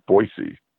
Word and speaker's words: Boise: But when you, like, watch one of the games where, like, the local announcers or whatever, you know, like, I believe they prefer it to be pronounced Boise. – Well, but, like, I feel like it should have, like, an extra E at the end if they really want it to be a C.